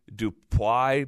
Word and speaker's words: Dupuy: D-U-P-U-Y. So phonetically, it's like Guy Dupuy, but really he's French, and the way he says it is Guy Dupuy.